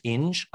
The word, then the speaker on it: Inge: There is the play, a play called Bus Stop by William, I think it's pronounced Inge, I-N-G-E, which became a movie starring Marilyn Monroe.